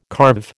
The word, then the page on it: carve: American English